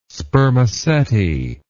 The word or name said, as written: spermaceti